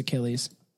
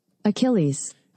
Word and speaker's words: Achilles: Pr- I'm gonna we're gonna find out how to pronounce this shit. Okay. Achilles. Uh, we were. It was Achilles. Achilles. Yep. So Billy was right.